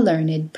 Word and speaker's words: learned: The following ED words used as adjectives are pronounced with id: aged, dogged, ragged, blessed, learned, wicked, crooked, naked, wretched. So we say, an aged man, a blessed nuisance, a dogged persistence, a learned professor, the professor who was truly learned, a wretched beggar, the beggar was wretched.